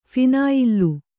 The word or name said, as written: ಫಿನಾಯಿಲ್ಲು